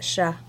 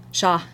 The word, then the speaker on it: chats: You don't pronounce that T? – We're still in France, so it's Sha. Sha, sorry. Sha. Le Sha, Le Sha, De Sha. And that's spelled C-H-A-T-S.